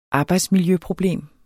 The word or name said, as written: arbejdsmiljøproblem